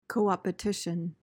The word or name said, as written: coopetition